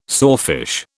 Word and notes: sawfish: British English

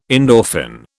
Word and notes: endorphin: British English

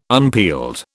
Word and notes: unpeeled: British English

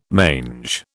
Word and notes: mange: British English

